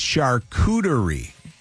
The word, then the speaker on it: charcuterie: It's charcuterie. That's how you pronounce it.